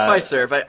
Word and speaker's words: Fiserv: Yeah, it's Fiserv. I looked it up a few minutes ago. That's how it's pronounced.